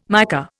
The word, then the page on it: mica: Indian